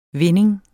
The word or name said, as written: vinding